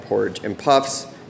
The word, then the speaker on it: Porridge and Puffs: We are at Porridge and Puffs, emphasis on the and, uh, right? – Yes.